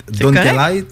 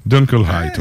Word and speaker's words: Dunkelheit: M'a dire Dunkelheit. – Dunkelheit, Dunkelheit. – Dunkelheit, ouais.